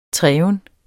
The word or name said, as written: treven